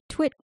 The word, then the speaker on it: TWiT: They call it TWIT, but it's spelled with case changes. So it's capital T, capital W, lowercase I, capital T.